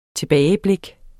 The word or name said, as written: tilbageblik